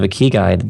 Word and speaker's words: ikigai: And what I want to bring up now is what you term, tell me if I'm saying this incorrectly, the ikigai. How do I say that? – Ikigai? It's that Japanese word, ikigai.